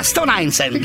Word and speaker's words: Stone Island: Stone Islands. – Ma perché scrivono? Scrivono come si legge. – Si chiama così, Stone Island.